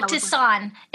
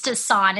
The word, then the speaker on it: tisane: Oh, tisane. It's tisane. It's a French word.